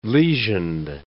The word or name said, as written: lesioned